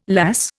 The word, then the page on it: lass: Indian